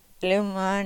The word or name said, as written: lumon